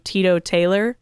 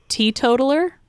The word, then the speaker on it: teetotaler: I think it's pronounced teetotaler. – Yes. – And she initially pronounces it Tito Taylor.